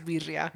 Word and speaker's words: birria: You said it wrong. It's birria. So how am I saying it? How are you saying it? – It's birria. – Birria. – You're saying it wrong. It's B. – B. – Re. – Re. Birria.